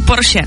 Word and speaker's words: Porsche: A pak tady ještě Porsche, jo. To my Češi říkáme skoro ze všech jako národů jediný správně. Všichni říkají Porsche špatně, ale je to opravdu Porsche.